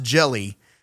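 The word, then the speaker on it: Jellö: And they were originally called green jello, but it was they had the umlaut over the O, and it was still pronounced jelly.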